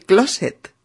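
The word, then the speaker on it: clóset: Se dice salir del closet, con acento en la O,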